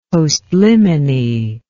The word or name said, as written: postliminy